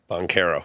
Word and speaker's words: Banchero: Banchero.